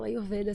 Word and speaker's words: Ayurveda: É assim que se pronuncia? Exatamente. Eu, de, de, várias né, é, palestras e, e, entrevistas que eu já dei, assim, tu foi o primeiro que falou Ayurveda, assim.